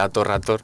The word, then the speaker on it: Hator Hator: Perdona que te corrija, pero se pronuncia Hator Hator.